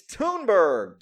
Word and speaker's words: Thunberg: It's Thunberg. Thunberg? The H is silent? Why the H silent? Why she got a fucking H in her name if it's silent?